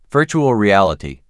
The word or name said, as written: Virtual Reality